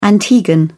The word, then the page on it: antiguan: British English